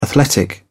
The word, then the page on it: athletic: British English